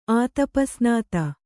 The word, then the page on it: ಆತಪಸ್ನಾತ: Kannada